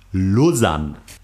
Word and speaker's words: Lausanne: Aber es wird ausgesprochen Lausanne. – Also richtig O.